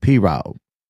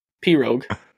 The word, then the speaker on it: pirogue: I said P-Rogue. – P-Rogue? – But the G's, I don't think the G's quite that enunciated. It's like P-Rogue. – The correct answer is P-Rogue. And you said it correctly.